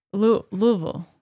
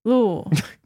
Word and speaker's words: Louisville: I'm a D1 swim coach at Bullring University in Louisville, Kentucky. – Yeah. I'm Reggie from Wisconsin. – That's what people love in Louisville. Louisville? – That's how you're supposed to say it. – Louisville. – Louisville.